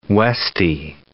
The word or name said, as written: Westie